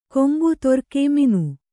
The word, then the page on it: ಕೊಂಬು ತೊರ್ಕೆ ಮೀನು: kombu torke mīnu